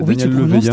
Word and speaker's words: Daniel Leveillé: Tout ça pour illustrer ce travail du Québécois Daniel Leveillé. Est-ce que je le prononce bien Oui, c'est Daniel Leveillé.